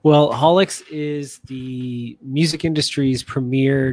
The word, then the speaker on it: Haulix: So obviously we have tonight yourself, uh, from uh, is it pronounced Horlicks and High Tones? – yeah, it's called Haulix, and the uh, podcast is called High Notes.